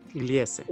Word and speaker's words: Gliese: Ahora vamos a, cuéntame, y aquí me vas a ayudar a, pronunciar porque es Gliese, o cómo se pronuncia. – Interesantemente, se dice Gliese 229. Gliese. Es Gliese, es, el código de una estrella enana marrón.